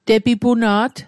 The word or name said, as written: tepipuna't